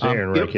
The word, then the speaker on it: Ehrenreich: Is Ehrenreich? Am I saying it correctly? I think so. – I think it's Ehrenreich. Yeah, Alden Ehrenreich.